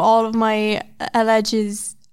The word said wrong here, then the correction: elegies: Elegies? – Elegies. – Elegize me. – Taylor Swift. – Elegies. How do you say that? – Elegies.